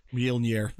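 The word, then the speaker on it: Mjölnir: I think, or is going to obviously wield Mjolnir. I can't even pronounce it properly. – Mjolnir.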